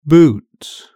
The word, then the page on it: boot: British English